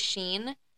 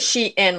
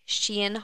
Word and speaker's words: SHEIN: I always called it Sheen, but I think you say it differently, and you're probably right. – I say it she in, like she girl in. – Shein. Okay, Shein.